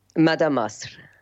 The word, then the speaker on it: Mada Masr: I'm not sure if I pronounced it correct. How do you say it? – Yeah, it's Mada Masr. – Mada Masr.